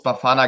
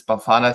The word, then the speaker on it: Bafana: Du hast es schon ganz gut ausgesprochen, aber du musst oder man muss Bafana gar nicht Englisch aussprechen. Also es heißt Young Bafana Soccer Academy. Das Bafana ist eigentlich das einzige Wort, was nicht Englisch ausgesprochen wird, aber es ist auch egal.